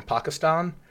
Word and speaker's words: Pakistan: It was a TED talk from Pakistan. – Okay. – That's the right way to say it, by the way. – Is it? – It's 2020. Gotta let the A's breathe.